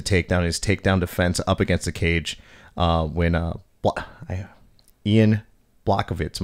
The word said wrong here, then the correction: Jan Blachowicz: Am I saying that correctly? – Jan Blachowicz, I believe. Yeah, Jan Blachowicz.